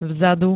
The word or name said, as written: vzadu